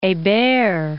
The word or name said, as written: Hebert